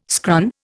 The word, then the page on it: scran: Indian